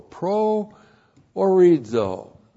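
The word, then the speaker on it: pro orizo (προορίζω): That's how it looks in the Greek, P-R-O-O-R-I-Z, long O, pro orizo.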